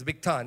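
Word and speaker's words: Bigthan: When those two fellows, Teresh and Bigtan, did I get the name right? Bighan, okay. No, Bigtan, I think. It's Bigtan. He has a T there.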